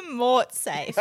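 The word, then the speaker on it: mortsafe: So have you ever heard of a mutsafe? Sorry. – A mutsafe? Is that how you pronounce it? What a mort. Can you just spell it? – A mort safe.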